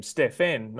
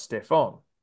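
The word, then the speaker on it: Stephen: So it came down to a Nike presentation where Curry's family actually felt the first piece of disrespect in this presentation, where they actually mispronounced his name, calling him Stefan, not Stefan.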